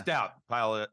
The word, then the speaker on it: Staudt: Staudt. Apologize for that. I'll just keep Guinness in my head.